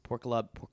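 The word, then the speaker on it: Porkalob: I believe it's pronounced Porkolob.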